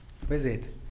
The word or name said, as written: bezed